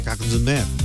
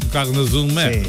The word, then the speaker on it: Cagnes-sur-Mer: A ver si lo digo, macho. Yo. Cagnes-sur-Mer. – Hombre, Cagnes-sur-Mer. – Cagnes-sur-Mer.